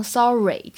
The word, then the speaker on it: sorry: 这句话呢，在朗读的过程当中注意一下，sorry，大家不要把末尾这个发成 a 哈，不要读成 sorry，这个是比较常见的一个错误。